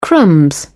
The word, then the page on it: crumbs: British English